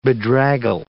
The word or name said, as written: bedraggle